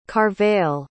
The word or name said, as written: carvell